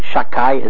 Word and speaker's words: Shakai: We don't pronounce it properly because it's one of the names of God. We pronounce it Shakai, as if the second letter was a kuf, and it means Almighty.